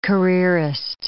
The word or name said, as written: careerist